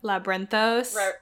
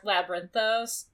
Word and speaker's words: Labyrinthos: Um, I don't know how to say this one, but like Labby Lab- Labyrinthos. – Labyrinthos. Thank you.